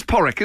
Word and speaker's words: Padraig: Is that how it's pronounced, Padraig? – No. – Porrick, is that something like that? It is Porrick, isn't it? – Is it? Porrick? – That's right. We're right.